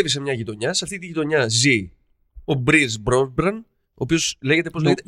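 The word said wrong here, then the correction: Pierce Brosnan: Η σωστή προφορά είναι Μπρις Μπρόσναν.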